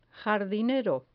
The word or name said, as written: jardinero